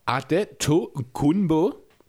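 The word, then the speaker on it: Adetokunbo: Sein größter Bruder, Francis Adeto Kunbo, das ist nämlich die nigerianische Aussprache,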